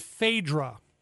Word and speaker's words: Phaedra: There was a song in the 1960s that talked about a goddess named Phaedra. I'll even spell it for you. Phaedra. Phaedra. – It's like pneumonia. – Okay. – The silent P.